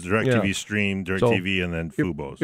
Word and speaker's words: Fubo: Well, I think the answer here now, is probably, is it pronounced Fubo? Fubo subscription. – I do believe so.